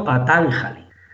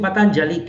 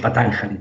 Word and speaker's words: Patanjali: Y eh, tú lo has pronunciado bien, porque eh, en español se pueden pronunciar las palabras como se escriben. Entonces está pronunciado Patanjali. O sea, tú lo has pronunciado bien. Lo que pasa es que yo pronuncio Patanjali, que también está bien, porque es como un término más en uso internacionalmente, pero que sepas que está bien, bien dicho Patanjali también.